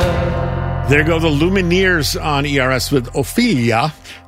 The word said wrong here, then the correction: Ophelia: I have no idea why I pronounced it like that. It's just Ophelia.